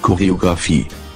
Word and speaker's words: Choreografie: Many German words that start with CH have a hard C sound, just like when English speakers say choreography. – Choreography. Die Choreografie. Die Choreografie.